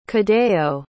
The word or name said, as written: cadeo